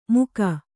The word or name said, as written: ಮುಖ